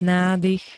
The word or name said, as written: nádych